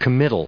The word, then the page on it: committal: com*mit*tal; American English